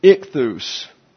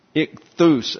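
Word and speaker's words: Ichthus (ἰχθύς): It's ichthus. Ichthus, actually, there's some accent is on the last syllable.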